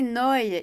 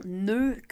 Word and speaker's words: Neu: Oui, donc prononcer Noy et non Neu comme il s'écrit et comment il euh, se prononcerait avec l'accent français, tu vois.